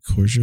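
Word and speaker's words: Koechner: How do you say his last name? Kosher. – Yeah.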